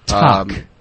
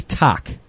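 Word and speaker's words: tahk: I'm probably pronouncing it wrong, but it's T-A-H-K. And the way that the pronunciation was written, it would be talk.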